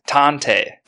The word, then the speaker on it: tantei: Detective's Japanese word is tante. Tante is spelled T-A-N-T-E-I.